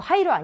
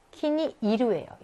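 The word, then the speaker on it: 키니 이루예요 (気に入る): 자, 키니, 이거는 어떻게 읽냐면 이거 하이루 아니야? 키니 이루예요, 이거. 마음에 들다. 얘만 키니 하이루라고 읽지 않고 키니 이루예요.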